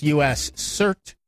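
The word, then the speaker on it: US-CERT: The United States Computer Emergency Readiness Team, which we already know is pronounced U.S. CERT, has discovered a new buffer overflow vulnerability with Apple's QuickTime Media software.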